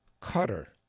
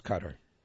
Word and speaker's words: Qatar: We turn to the briefing section for a story about the Nation, spelled Q-A-T-A-R. And the author of this article informs us that the correct pronunciation for that is Qatar, as opposed to the commonly heard Qatar.